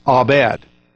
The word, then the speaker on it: abad: Strong's Concordance, the Hebrew definition of work is pronounced abad. Now, that's how it's pronounced.